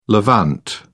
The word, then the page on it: levant: British English